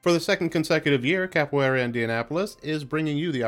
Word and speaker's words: Capoeira: Glad I googled how to say this. Capoeira. – Capoeira.